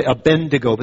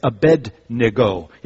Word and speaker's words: Abednego: A lot of people say Abednego, but actually that's not correct. It's Abednego. You have to say it like you have a cold.